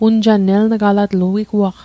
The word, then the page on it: eln'galatl: Mi'gmaq